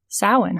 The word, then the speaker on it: Samhain: Samhain, huh? – Sometimes pronounced as Samhain.